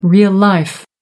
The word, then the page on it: real life: American English